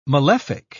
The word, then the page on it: malefic: British English